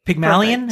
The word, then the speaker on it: Pygmalion: But it starts with, in ancient Greek mythology, Pygmalion, is that, am I saying that right? – Yep.